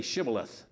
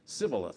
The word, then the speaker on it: Shibboleth: Can you say Shibboleth? And of course, the Ephraimites couldn't pronounce their H's, and so they would say Sibboleth.